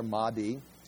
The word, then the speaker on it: Mahdi: And again, I've got pronunciation there, Mahdi.